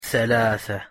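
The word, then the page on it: ثلاثة: Arabic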